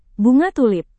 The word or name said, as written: bunga tulip